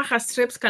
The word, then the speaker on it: Jastrzebska: Recibimos ya a Iwa, que no me atrevo a decir tu apellido, Iwa, porque siempre lo digo mal. ¿Cómo estás, bella? – Hola, ¿cómo estás? Pues es Iwa Jastrzebska.